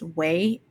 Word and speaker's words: Ouai: So the Self-Care Sunday comes with a sample of North Bondi by, I think it's pronounced Way. That's spelled O-U-A-I. But everybody on TikTok pronounces that Way. So we'll call it Way.